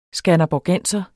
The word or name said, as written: skanderborgenser